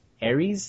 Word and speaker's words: Aries: Yeah, how do you pronounce his name? – Aries Spears. – Arius, whatever.